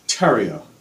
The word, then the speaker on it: Terrio: Terrio, as you say, stereo, but leave off the S.